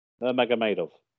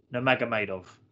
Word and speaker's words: Nurmagomedov: Nurmagomedov. – Khabib Nurmagomedov.